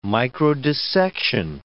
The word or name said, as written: microdissection